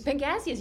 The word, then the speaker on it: Pangasius: And then there's another fish that I can't pronounce, Pangasius. – Pangasius, you did it, you nailed it.